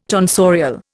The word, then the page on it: tonsorial: Indian